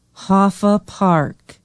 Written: hofa park

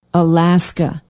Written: alaska

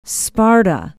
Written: sparta

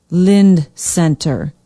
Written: Lind Center

